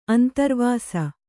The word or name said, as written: ಅಂತರ್ವಾಸ